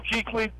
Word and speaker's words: Kuechly: You think that's not going to help you with experience when Ely and Short and Lely, whatever his name is. – What? Whoa! Wait, there's not a pronunciation guy. – I gotta get that one right before I go on air. I'm glad I screwed that up with you guys. But Luke Kuechly, the rest of those boys coming after you, I think it'd be a little different story.